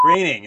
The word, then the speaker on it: Groening: Simpsons creator Matt Groening. It rhymes with raining.